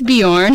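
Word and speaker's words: Björn: Also, we weren't sure how to pronounce his last name. Yeah. It's Bjorn.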